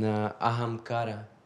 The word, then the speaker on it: ahamkara: And ahamkara, which is ahamkar, mm-hmm. right? The same.